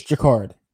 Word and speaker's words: Jacquard: Am I saying that correctly? – I think you are. – The C and the Q are always tough. And I even took it. It's Jacquard. Jacquard.